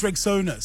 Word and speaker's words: Rexona: It's pronounced Rexona. Simple, strong, and easy to remember.